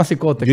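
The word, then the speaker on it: Cicotte: Eddie Cicote, pero no se escribe como Cicote, pero se llama Cicote.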